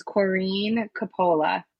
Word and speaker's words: Corinne Coppola: And it's just to let you know, the pronunciation is Corinne Coppola. – Corinne Coppola. Thank you so much for correcting me, because I probably would have called you Corinne the whole time.